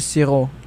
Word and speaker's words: sirop: French is Siro, S-I-R-O-P. – S-I-R-O, exactly. – Yeah. And it's pronounced Siro, still, Sirop, Siro, ouais.